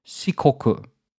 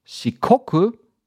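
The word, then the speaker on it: shikoku (四国): But when you pronounce the word shikoku, it's very close to the Japanese word for square, for the, you know, the geometrical form of a square, which is shikaku. So shikoku, four borders, shikaku, basically four angles.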